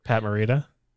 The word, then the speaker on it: Pat Morita: Pat Morita.